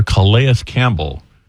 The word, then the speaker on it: Calais Campbell: You are referring to former Jaguar Calais Campbell, also known locally as the mayor of Saxonville.